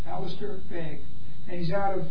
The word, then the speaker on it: Begg: How do you spell that last name? – B-E-G-G. – Huh? – B-E-G-G. – B-E-G-G. – Begg. – Big J. – Begg. – Begg, that's his name.